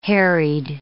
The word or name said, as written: harried